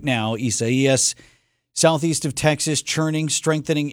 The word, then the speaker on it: Isaias: So that is the pronouncer, Isaias? – That's what I've seen multiple times over, because there are some other mispronouncers out Esaias. – There. Esaias. – Esaias. Esaias. Okay.